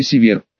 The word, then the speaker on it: Север: При рождении родители мне дали редкое Север, да, с ударением на второй слог. Якобы это имя носили какие-то римские полководцы или императоры, а на латинском языке имя звучит как Северус.